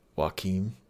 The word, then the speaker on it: Joaquin: How do you pronounce his name? – Joaquin. – Joaquin? Okay. I've never heard it said aloud, so...